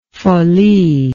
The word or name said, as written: folie